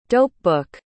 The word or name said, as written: dopebook